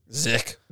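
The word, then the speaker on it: Zech: It's Z-E-C-H. – Zick. – Yeah, that's right.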